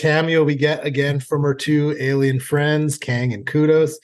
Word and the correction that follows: Kodos: Do I say that right? I always say Kudos, but I think it's Kodos. – Kodos. Yeah.